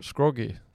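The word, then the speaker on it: Scroggie: Det är, ju med 2G och IE istället. Ja. Scroggy.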